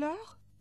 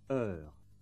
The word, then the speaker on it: heure: Remember that consonants at the end of words are not usually pronounced. For example, you don't hear the S at the end of heure, nor the T at the end of gare.